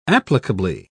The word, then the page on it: applicably: British English